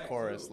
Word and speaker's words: Chorus: It's pronounced Chorus, like singing.